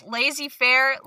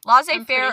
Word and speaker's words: laissez-faire: How do you say this word? Laissez-faire. – You wrote it. – Laissez-faire. – Laissez-faire. – Laissez-faire. – You wrote this. – I know the word and how it looks, but I don't know how to pronounce it. – Laissez-faire. – Laissez-faire, sure.